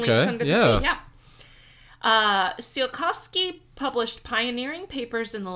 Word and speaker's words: Tsiolkovsky: Although not involved directly in the creation of the space program, the spiritual grandfather was early rocket scientist Konstantin Tsiolkovsky.